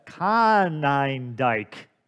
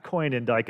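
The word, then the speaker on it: Konyndyk: First, I want to, I've been pronouncing the name of the author of the book, Glittering Vices, Dr. Rebecca Conindyke, not Coinindyke as I've been saying it.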